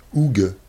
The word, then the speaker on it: Hougues: On rappelle, ça s'appelle Terre des Zougs. Ça s'écrit H-O-U-G-U-E-S. Oug.